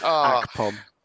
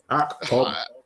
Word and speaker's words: Akpom: Akpom. – Akpom.